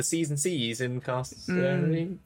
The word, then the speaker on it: Carceri: No, Carceri, hard C. I believe according to Chris Perkins, it's like incarceration. So Carceri.